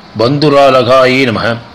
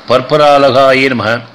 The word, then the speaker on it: பற்பராலகாயே நமக: அடுத்த நாம பந்துராலகாயே நமக. இந்த நாமாவை வேறு விதமாகவும் உச்சரிக்கலாம், அதாவது பற்பராலகாயே நமக. இந்த இரண்டாவதாக சொல்லப்பட்ட வந்து பற்பராலகாயினமக அப்படிங்கிற நாம தான் வழக்கத்தில் இருக்குது.